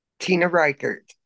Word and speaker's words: Tina Reichert: Tina Reichert.